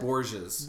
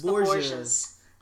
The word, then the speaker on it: Borges: How do you say that? – Borges. – Borges. Well, I'm sorry. I was going to say it Françaises. – But it's like, Italian or Spanish. – All right. – It's Spanish, actually.